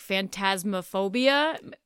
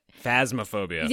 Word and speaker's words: Phasmophobia: Oh, I'm going to butcher the title, like Phantasmophobia. – Phasmophobia.